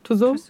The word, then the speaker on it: Tussauds: Und dann haben wir noch Madame Tussauds. – Habe ich richtig ausgesprochen? – Tussauds.